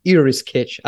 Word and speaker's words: IRRISketch: So let's start with the proper pronunciation. It's IRRISketch. I think that it's not properly transposed to English.